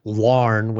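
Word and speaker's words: Laugharne: In 1976, I went to Wales, and in order to get to the little village where he lived, which is Larn, which is one syllable, but it's spelled like it's 20 syllables. it's L-A-U-G-H-A-R-N-E, Larn, a tiny village.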